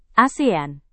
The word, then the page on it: asean: indonesian